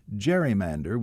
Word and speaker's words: gerrymander: One of the oddities of the new creation is that for some time now it has been pronounced gerrymander with a soft G as in gentle, but the man for whom it is named pronounced his last name Gerry with a hard G as in go.